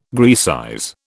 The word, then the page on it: Graecize: British English